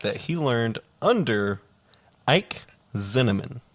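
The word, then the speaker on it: Zimmerman: It's with a Z. Zinnemann. – Zinnemann. Oh, God. It sounds like cinnamon. It sounds like... – I could go for some cinnamon rolls, though. – Zinnemann.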